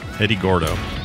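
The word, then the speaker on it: Eddy Gordo: Eddie Goro? Eddie Goro, I think is how you say it. Gordo? Eddie Gordo.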